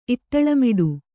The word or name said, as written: ಇಟ್ಟಳಮಿಡು